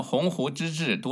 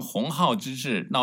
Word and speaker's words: 鸿鹄之志: 10月末，北京大学领导重新洗牌，在北大 120年校庆致辞时，将鸿鹄之志读成鸿浩之志，闹出笑话。